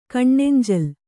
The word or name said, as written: ಕಣ್ಣೆಂಜಲು